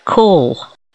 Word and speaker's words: caul: This is spelt C-A-U-L and pronounced call.